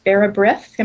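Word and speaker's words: bara brith: And the bake shop has wonderful Vera Brith.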